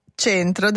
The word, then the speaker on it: c'entro: Allora tradizionalmente centro dal verbo entrarci alla E chiusa.